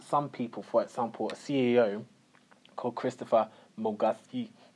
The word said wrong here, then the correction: Mwanguzi: Ooh, it's so complicated. Can you actually pronounce that surname? Let's see, because you know English is a second language. – Christopher Mwanguzi.